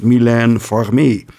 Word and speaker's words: Mylène Farmer: Maar volgens mij, als ik dat op zijn Frans doe, op mijn beste Frans, dan was het Mylène Farmer.